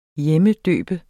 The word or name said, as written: hjemmedøbe